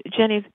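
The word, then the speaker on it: Ginnie: It's a little Jenny. It's G-I-N-N-I-E, Jenny from Virginia.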